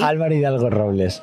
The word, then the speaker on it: Álvaro Hidalgo-Robles: Álvaro Hidalgo-Robles.